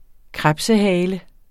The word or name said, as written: krebsehale